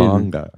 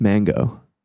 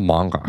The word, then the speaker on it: manga: It's just it was a cartoon manga. – It's mango. – It's pronounced manga.